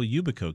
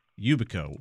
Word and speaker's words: Yubico: Also, you can do a physical Yubico key.